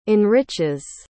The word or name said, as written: enriches